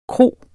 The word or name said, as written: KROERNES